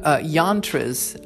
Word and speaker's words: yantra: and you can put up yantras in a mantra yantra coloring book, I should say, Yantra. So it looks like it rhymes with mantra, but it's a Y.